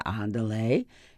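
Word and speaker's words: Ándale: Of course, Andale is Spanish, pronounced Andale, meaning let's go, and the shepherds obviously were driving the sheep, but not obvious to me. Now, to be fair, as I recall, the whole section was in italics, and there was no accent on the first A.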